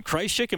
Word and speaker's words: Krzysik: Jamie Kreischik joining us tonight. I'm saying your name right. I know you told me this afternoon. Kreischik, am I doing that right? – Kreischik.